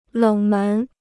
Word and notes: 冷门: lěng mén

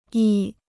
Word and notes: 亿: yì

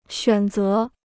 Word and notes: 选择: xuǎn zé

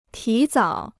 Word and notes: 提早: tí zǎo

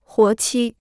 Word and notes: 活期: huó qī

